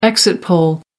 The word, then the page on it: exit poll: American English